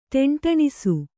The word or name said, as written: ಟೆಂಠಿಣಿಸು